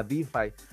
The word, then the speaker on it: DeFi: Es lo que puso Don DeFi, DeFi, que no desafíes a DeFi, porque es un juego de palabras, porque DeFi en inglés también significa desafiar, entonces se, pronuncia exactamente igual.